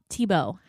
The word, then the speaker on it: Thibault: Hey everyone, joining me on the phone is Guy Paul Thibault.